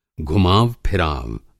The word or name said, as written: घुमाव फराव